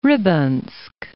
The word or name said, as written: Rybinsk